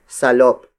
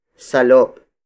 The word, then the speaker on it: salop: A much larger unit for volume is salop. Salop. Salop. Salop is spelled as S-A-L-O-P.